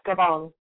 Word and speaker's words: Gabon: Gabon. Is that how you pronounce it? – Yes, Gabon.